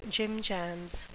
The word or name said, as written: jimjams